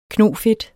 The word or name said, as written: knofedt